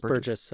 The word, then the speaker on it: Burgess: I think it's Burgess. It's a soft G.